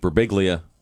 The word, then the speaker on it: Birbiglia: Joining us in the studio, comedian Mike Birbiglia.